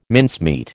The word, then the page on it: mincemeat: American English